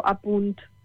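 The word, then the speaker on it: À Punt: Allora, io vi consiglio seguire Apunt, che è la televisione pubblica valenziana, e si scrive Apunt, come lo dico, Apunt,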